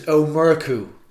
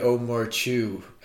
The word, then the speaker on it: O'Murchu: And how do you pronounce your second name? Because I'm Italian, I can't pronounce it. – It's Omurku. – Omurku, okay. – So it looks like Omurchu.